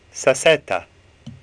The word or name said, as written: Sassetta